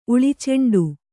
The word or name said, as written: ಉಳಿಚೆಂಡು